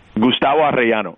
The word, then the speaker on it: Gustavo Arellano: Gustavo Arellano wrote a story about his impact in the Latino community. – You pronounced the hell out of that, my friend.